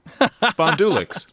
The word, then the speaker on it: spondulix: That's P-I-N-K-C-L-O-U-D-S. Plus S, as in Sam. – Really? Pink clouds. – Spondulux. No. – You got it. – What? – Spondulix. – Spondulix. – I always said spondulix with the accent of the second syllable.